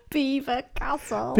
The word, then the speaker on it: Belvoir: but I was calling it Belvoir Castle, but what's it actually called? – Beaver Castle. – Beaver Castle. Now, I have no idea. It's spelt Belvoir, but it's pronounced Beaver,